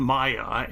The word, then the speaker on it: Maija: Then we begin with Maya, and you spell her name as M-A-I-J-A, but she pronounces it like M-A-Y-A.